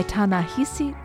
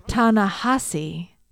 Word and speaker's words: Ta-Nehisi: By Ta-Nehisi, no, sorry, I knew I'd get this wrong. Ta-Nehisi Coates.